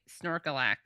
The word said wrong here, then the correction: Snorlax: Snor- snorlax.